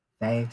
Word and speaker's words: Fae: So that's just at Faye, so F-A-E, and then my last name, which is incredibly long, which is M-A-U-R.